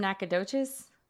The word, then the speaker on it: Nacogdoches: She's from, you're from Nacogdoches? – The oldest town in Texas. Thank you for saying it correctly.